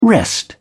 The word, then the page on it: wrest: British English